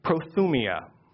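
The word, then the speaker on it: prothymia: The word eagerness, again, please pronounce this after me, is pronounced prothumia.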